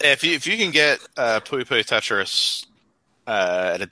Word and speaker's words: Puyo Puyo Tetris: Puyo Puyo Tetris.